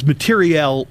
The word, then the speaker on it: materiel: Did you just say materiel? – Yeah. Like war materiel. – Materiel. – Use that pronunciation properly. – Do you really? – I want to point out. – Right. – No. It's materiel with an E, meaning ordnance and, materiel. It's a military term, I'm sure.